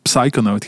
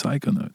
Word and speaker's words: Psychonaut: En ik Zwaar, heb ook uh, tien jaar lang psychonaut gezegd. Ja, goed. Tot iemand zei, nee, het is psychonaut. psychonaut. – Je mocht die P niet uitspreken. Psychonaut.